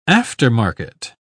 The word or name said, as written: aftermarket